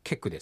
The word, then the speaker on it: ケク: は何々からですね、どっから来たのって聞かれてるから、札幌から、札幌はケクですね。クエクですけど、猿方言ですからケクというふうに発音されるということですね。